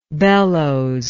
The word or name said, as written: bellowslike